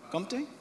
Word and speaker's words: Comté: Is it Comté? Comté? Excellent, thank you.